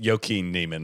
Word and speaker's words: Joaquin Niemann: Your reaction, your guy Waco. It's Joaquin Niemann. That's right. Yeah. I'm sorry. I mispronounced that.